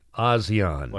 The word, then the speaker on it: ASEAN: ASEAN. ASEAN. – Like the cheese? – No, like the area, Asian.